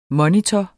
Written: MONITORER

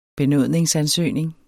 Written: benytter

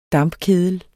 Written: dampkedel

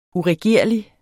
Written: uregerlig

